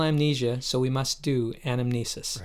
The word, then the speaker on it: anamnesis: The Greek word is anamnesis, which kind of sounds like the word amnesia.